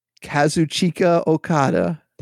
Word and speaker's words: Kazuchika Okada: Kazuchika Okada. – Kazuchika. Okada. – It almost sounds Italian, Kazuchika.